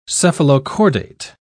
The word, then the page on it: cephalochordate: British English